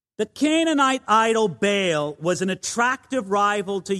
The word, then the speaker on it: Baal: I am aware, I'm almost certain that I mispronounced this and that the actual pronunciation of this God should be Baal. However, I've been saying Baal for so many years, I find it impossible to say Baal.